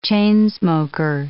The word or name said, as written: chain-smoker